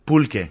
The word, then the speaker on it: pulque: Pronounce it one more time for me. P-U-L-Q-U-E. – Pulque.